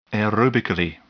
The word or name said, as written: aerobically